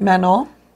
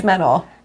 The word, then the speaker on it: Manon: Manon. – See, now you're saying it. – Manon. – Spell it. – M-A-N-O-N.